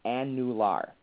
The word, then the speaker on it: annular: And that's why I purposely make my students pronounce it as annular. And it's spelled A-N-N-U-L-A-R.